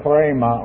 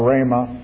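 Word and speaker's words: rhema (ῥῆμα): and that's the word chrema or rhema, which is an easier English pronunciation.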